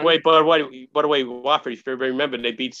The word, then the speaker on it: Wofford: W-O-F-F-O-R-D. It's pronounced Wofford.